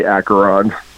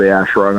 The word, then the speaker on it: Acheron: But you know, Acheron, actually, that's the most people say Acheron, but that's why I say Asheron, how we pronounce it.